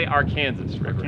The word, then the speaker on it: Arkansas River: Well, locals say Arkansas River.